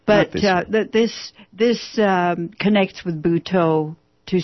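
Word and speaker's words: Butoh: Okay, now I have friends who would be extremely interested in that because they like Japanese art and they like Buto. Is that how you pronounce it, Buto? – Buto, yes.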